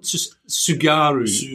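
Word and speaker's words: Tsugaru: Sugaru Strait.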